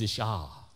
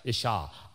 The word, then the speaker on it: Isha: Now, the Hebrew name for woman is Isha. Isha. I-S-H-A. Let me make sure I spell that right. Okay. Yeah. I-S-H-S-H-A.